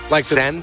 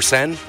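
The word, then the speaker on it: Seine: On the river Sen? – Like Neyland? – River Sen? – Wow. – Is that how you pronounce it? – Yeah, they're coming down barges, and they end up like exiting by the Eiffel Tower or something like that.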